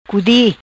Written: Cudi

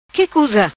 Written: Cuza Que

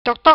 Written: Toc Toc

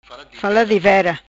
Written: fala divera

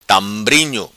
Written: Tambrinho

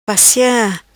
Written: Passia

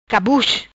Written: Pesse Cabus

